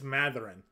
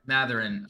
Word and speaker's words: Mathurin: It's apparently it's Mathurin. I just looked up the pronunciation there. – Mathurin. I listened to him enunciate it, and it was like Mathurin. – Yeah, Mathurin. When I listened to him in his audio file.